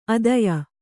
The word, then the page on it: ಅದಯ: adaya